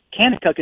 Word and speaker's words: Kanakuk: Kanakuk is spelled K-A-N-A-K-U-K. Kanakuk. Everything with a K there.